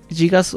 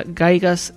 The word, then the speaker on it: Gygax: Dice Gigas, la pronunciación correcta, Gygax, es genial, dejando de fuera todas las historias no oficiales respecto a él.